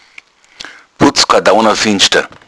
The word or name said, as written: putzkardauner